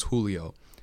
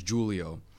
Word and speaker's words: Julio: which is in Spanish languages Julio. It's pronounced Julio. But my father is Julius, and we came from a country where the second language is French. So we use the hard J, which is Julio.